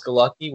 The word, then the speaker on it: Galucki: My channel is it's just my name, Alex Galucki, which is spelled G-A-L-U-C-K-I.